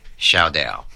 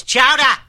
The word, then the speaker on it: chowder: Shaudel. – Shaudel. Shaudel. It's chowder. Say it right.